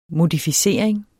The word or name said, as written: modificering